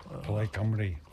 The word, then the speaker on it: Plaid Cymru: On my way to Cardiff for a historic meeting of Sinn Féin, the SNP, or the Scottish Nationalist Party, and Plaid Cymru. I think that's how you say it. – Plaid Cymru.